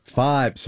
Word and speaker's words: Fybe: Am I saying this right? Fiber? – Fibe. – See, I believe. – Yeah. – Yes. F-Y-B-E. – F-Y-B-E. Fibe.